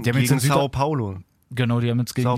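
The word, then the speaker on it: São Paulo: Sao Paulo, ja.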